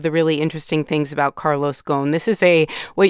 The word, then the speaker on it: Carlos Ghosn: Yeah, so Carlos Ghosn, which is how you pronounce his name in Arabic, is a Lebanese citizen.